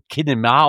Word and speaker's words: Kinema: Mit dem Kinema habe ich, mit K geschrieben, aber wird dann wirklich so Kinema ausgesprochen, von Drusse nach Drinne.